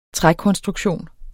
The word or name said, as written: trækonstruktion